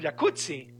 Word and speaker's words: jacuzzi: Entonces deberíamos pronunciar el jacuzzi. – Exacto. – Como pizza. De... como rampazzo, sí. Como Claudia Rampazzo.